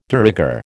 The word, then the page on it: de rigueur: American English